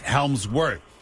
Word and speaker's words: Hemsworth: Chris Helms. Chris Hemsworth. – Hemsworth, but I'll take it. – Hemsworth. Chris, yes. There's an L in there, isn't there? It's just mispronounced. Is there an L? – Nope.